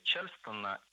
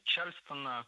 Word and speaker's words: Чарльстон: Да, элементы степа, Чарльстона и, конечно же, прыжки и пробежки по паркету. – Чарльстон – это неверно. Правильное название – Чарльстон. Ударение в этом слове должно падать на второй слог.